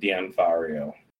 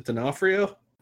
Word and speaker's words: D'Onofrio: It's like D, comma, Onfario. So it's like Dion. – D'Onofrio. – D'Onofrio.